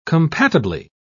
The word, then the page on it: compatibly: British English